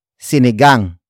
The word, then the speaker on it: sinigang: And that is sinigang. Sinigang. Sinigang. Sinigang is spelled as S-I-N-I-G-A-N-G.